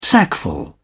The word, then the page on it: sackful: British English